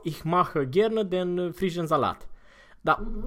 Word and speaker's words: Salat: Der frische Salat. – Cu T la urmă. Salat.